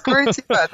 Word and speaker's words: Curitiba: So Gabriela Villar is the chef owner of Quintana restaurant located in Cur- Curitiba, did I say it correctly? Brazil? Yes, Curitiba. that's it. Valar has found a way to create a business around her passion of respecting the environment, art, culture, tradition, and food.